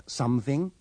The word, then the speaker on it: something: In these sentences, remember to pronounce the O in something, somebody, and someone with the sound of the U in cut.